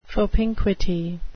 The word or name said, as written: propinquity